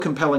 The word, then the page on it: compelling: American English